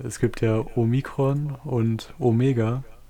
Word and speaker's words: Omega: Genau, ein Omega ist ein langes O und ein Omikron ist ein kurzes O.